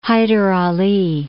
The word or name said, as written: Haidar Ali